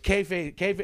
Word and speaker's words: kayfabe: So it's kayfabe. It's spelled weird, but it's pronounced kayfabe.